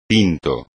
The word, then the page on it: tinto: espagnol